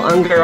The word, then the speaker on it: Ungar: We have the fascinating Dr. Michael Unger on the show, and I keep worrying I'm not saying your name right. Am I saying it right? – Yeah, U-N-G-A-R, and it rhymes with hunger.